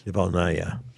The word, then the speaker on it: Navalnaya: Navalnaya.